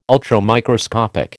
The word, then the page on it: Ultramicroscopic: American English